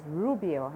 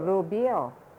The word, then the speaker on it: Rubio: Like my last name is American way, they pronounce R-U-B-I-I-O is Rubio, huh? – Yeah. – But Filipino, Rubio.